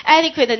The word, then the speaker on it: adequate: Adequate 呢？这单词你看它发音，adequate。安得夸他，adequate，对不对？